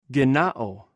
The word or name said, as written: gennaw